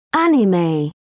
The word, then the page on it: anime: British English